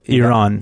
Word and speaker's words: Iran: But how do you say it for real? – Iran. – Iran, not Iran.